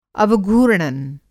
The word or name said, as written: अवघूर्णन